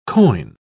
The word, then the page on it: coign: British English